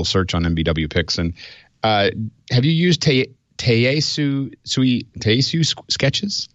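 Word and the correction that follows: Tayasui: I don't know if I'm saying it correctly. – Taiyasui. – Taiyasui Sketches.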